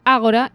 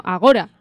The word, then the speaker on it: Ágora: Ya me están entrando dudas de cómo se pronuncia, porque yo hubiera pensado que se pronuncia Ágora y no Agora.